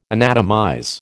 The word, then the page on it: anatomize: American English